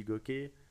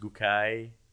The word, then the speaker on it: Gookay: Euh, après, c'est sur la prononciation. Moi, je dis goké euh, par simplicité. Il y en a qui disent gokai, gokai.